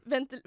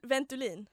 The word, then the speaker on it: Ventolin: Ventil Ventolin.